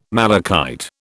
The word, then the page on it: malachite: British English